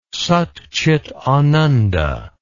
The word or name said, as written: satcitananda